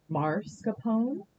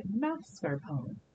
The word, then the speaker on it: mascarpone: Most people come out to the cheese counter looking for mascarpone, but it is actually mascarpone. The R is a little bit further into the word than we tend to think it is.